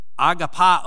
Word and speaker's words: agape: Love, particularly this agape love that's being used here, you remember in the Greek language there were several words for love, one of which is agapao, or agape, as we like to pronounce it.